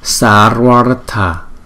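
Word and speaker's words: Sarvārtha: Hai chữ A ngắn liên tiếp với nhau chúng ta ráp lại thành một chữ A dài, cho nên một cái chữ này chúng ta sẽ đọc là Sarvartha, Sarvartha.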